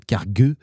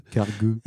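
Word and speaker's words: Cargö: Le Cargo, avec son tréma sur le haut, donc ça se prononce en fait Cargueux. – Cargueux.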